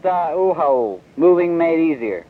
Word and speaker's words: U-Haul: Well, they may be pronouncing it, but it's just Uhaul, like you haul something. U-H-A-U-L.